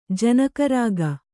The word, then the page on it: ಜನಕರಾಗ: janaka rāga